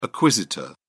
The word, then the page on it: acquisitor: British English